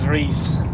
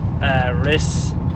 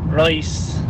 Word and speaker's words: Rhys: Since I was a child, my name is Rhys. It's R-H-Y-S spelled. It's a Welsh way of spelling it. And everybody calls me Rhys, Rice, everything and anything but Rhys.